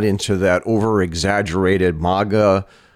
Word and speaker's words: manga: Well, MAGA is a mispronunciation on your part. Okay. MAGA is Make America Great Again. – And oh, okay. – Manga is a Japanese, it's a style of anime.